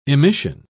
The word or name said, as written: emission